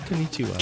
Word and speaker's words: Konnichiwa: Wrong emphasis on the wrong syllable. – Konnichiwa. – I don't remember what she said. – I think that was in Moana. – Isn't it Konnichiwa? – Yeah, it's Konnichiwa.